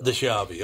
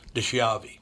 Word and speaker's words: DiSchiavi: It is DiSchiavi in Italy, but in America we pronounce it DiSchiavi. – So. DiSchiavi. Okay. Well, Steve, hey, whatever. I just want to make you comfortable. DiSchiavi is how you pronounce it.